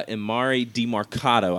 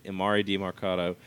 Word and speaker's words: Emari Demercado: for TCU uh, Emari Demercado. I finally figured out how to say it. Emari Demercado, uh, 14 carries, 59 yards, no touchdowns.